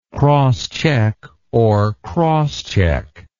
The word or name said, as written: crosscheck